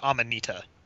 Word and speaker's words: Amanita: I've always said it Amanita. I think that's correct.